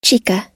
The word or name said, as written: chica